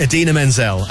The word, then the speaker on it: Idina Menzel: one and only Adele Dazeem. – You pronounce it Idina Menzel.